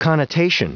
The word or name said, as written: connotation